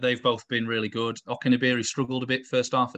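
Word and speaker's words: Okenabirhie: and I'm going to pronounce this incorrectly, but I believe it's Onikaberi is the name. – Okeneberi. – Okeneberi. I was close.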